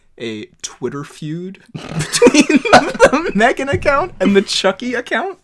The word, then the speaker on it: M3GAN: It's pronounced Megan.